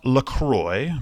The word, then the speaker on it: LaCroix: It's pronounced LaCroix, but it's spelled L A C R O I X.